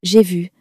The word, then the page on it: j'ai vu: French